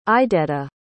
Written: idetta

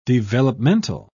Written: developmental

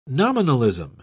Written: nominalism

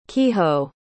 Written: kiho

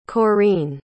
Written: correen

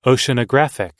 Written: oceanographic

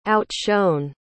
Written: outshone

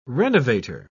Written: renovator

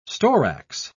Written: storax

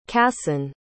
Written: kasen

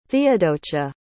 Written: theodocia

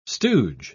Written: stooge

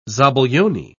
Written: zabaglione

